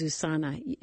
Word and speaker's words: USANA: We also received a number of questions because when we were on that topic, you mentioned one of your favorites is USANA.